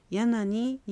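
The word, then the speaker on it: ヤン アニ: このヤン、アニは続けて発音してヤナニになっても構いません。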